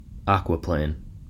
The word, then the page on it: aquaplane: American English